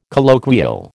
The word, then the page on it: colloquial: American English